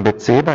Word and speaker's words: Bathsheba: David en Bathsheba, ja, zeg ik het goed? Ja.